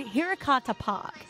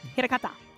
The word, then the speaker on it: Hirakata: This theme park is called Hirakata Park. It's in Osaka. – Is that how you pronounce it? – Yeah, I did Japanese for my leaving exams.